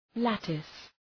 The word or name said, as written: lattice